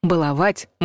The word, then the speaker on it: баловать: Ударение в этом глаголе нужно ставить так — «баловать», «балую», «баловал». Независимо от значения слова — «шалить» или «изнеживать». Это литературный вариант произношения, зафиксированный орфоэпическими словарями.